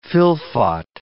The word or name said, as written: fylfot